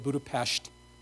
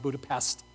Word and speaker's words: Budapest: And so there I was in Budapest, and yes, that's how you say it, Budapest, not Budapest. It's Pest. I don't have a lisp.